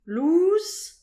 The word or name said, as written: lous